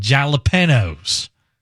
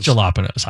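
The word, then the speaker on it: jalapeños: Detroit-style pepperoni pizza with hot honey and jalapenos. I know that's not how you say it. Eggplant parmesan. – It's jalapenos, obviously. Eggplant parmesan, giant meatballs.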